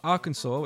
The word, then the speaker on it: Arkansas: We got Arkansas, which is not pronounced Arkansas, even though it's fucking spelt the same.